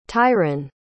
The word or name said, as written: tyren